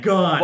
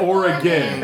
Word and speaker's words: Oregon: The Oregon... – I'm not listening. – Portland, Oregon. Duh. – Oregon. – Oregon. – Oregon. – Oregon. – Oregon. – It's G-O-N. Gone. – Fuck you. It's Oregon.